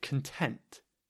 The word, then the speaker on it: content: You know, it's spelt the same as content, but because it's an adjective, we're saying content. Content. So the stress is on the second syllable.